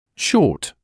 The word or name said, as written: short